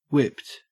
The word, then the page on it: whipped: Australian English